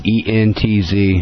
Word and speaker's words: Entz: ENTZ. It's pronounced Entz.